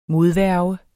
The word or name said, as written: modværge